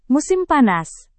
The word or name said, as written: musim panas